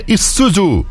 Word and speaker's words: Isuzu: É, você escreve Isuzu, mas a pronúncia é Isuzu.